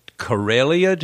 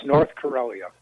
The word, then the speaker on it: Karelia: I think it's called Karelia. Did I pronounce it correctly? – Yes, North Karelia. – North Karelia.